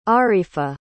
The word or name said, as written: arifah